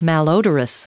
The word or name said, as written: malodorous